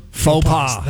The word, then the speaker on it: faux pas: Faux pas. – That's it. – So this is many faux pas. – You pronounce the S. That's your plural.